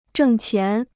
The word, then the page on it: 挣钱: zhèng qián